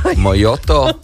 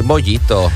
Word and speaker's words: mojito: Jā, nevis mojito vai mojoto. Kā viņi arī tik vien neizrunā. Daudz bāru apmeklētāji, kas nezin, jā. Ir mojito, mojito. – Mojito.